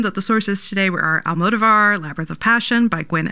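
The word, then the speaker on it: Almodóvar: It's Almodovar, but sometimes my mouth wants to do something else.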